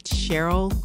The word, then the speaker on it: Cheryl: They can find me at Cheryl Grace, CEO, Powerful Penny. – And that's Cheryl without the E. – So that's C-H-E-R-Y-L, because, you know, I spell it the right way.